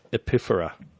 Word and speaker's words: epiphora: E-P-I-P-H-O-R-A. – How do you pronounce it again? – Epiphora. – Particular. – No, epiphora, E-P-I. – Oh, right. – P-H-O-R-A. Yeah.